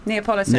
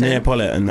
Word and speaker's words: Neapolitan: Yeah, how do you pronounce it again? – Neapolitan. – Neapolitan, that's it.